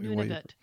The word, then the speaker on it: Nunavut: Is that Nunavut.